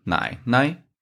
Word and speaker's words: 奶奶: Mama, The third pinyin, 奶奶. The second syllable sounds higher compared to the other three. It's because when a neutral tone after a third tone, we're reading a higher pitch